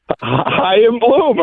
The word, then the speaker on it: Chaim Bloom: Chaim Bloom.